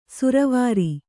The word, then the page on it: ಸುರವಾರಿ: suravāri